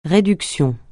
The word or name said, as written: réduction